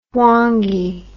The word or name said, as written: hwange